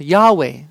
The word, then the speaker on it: Yahweh: It was only consonants at that time, and that was the name God chose to reveal to himself, and it's pronounced Yahweh.